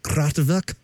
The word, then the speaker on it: Kraftwerk: And I thought about this old song that Kraftwerk did. Is that the correct pronunciation? Kraftwerk? – Yeah, that's Kraftwerk. – That one is, yeah.